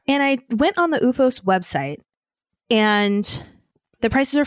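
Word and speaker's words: OOFOS: And so I bought UFOs. – How do you spell it again? – Okay, so it's O-O-F-O-S.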